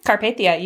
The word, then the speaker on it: Carpathia: So after they made it onto the Carpathia, I'm saying that right? Carpathia? – Mm-hmm. Carpathia, you got it.